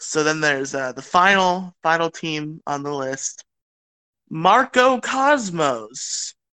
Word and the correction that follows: Macro: Or Macro, depending how you want to pronounce it. – Oh, it is Macro. It's spelled M-A-C-R-O.